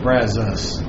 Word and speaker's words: Brazos: It's the Texas kind of lazy tongue thing, Brazos. – Brazos. – Brazos.